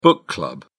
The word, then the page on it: book club: British English